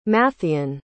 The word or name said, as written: matthean